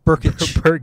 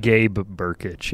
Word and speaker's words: Berkich: Well, Gerbic, is that how you say it? – Berkich. – Berkich. Starts with a B, not a G. – B-R-K-I-C. – Yeah. Gabe Berkich.